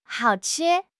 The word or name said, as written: hao3 chi1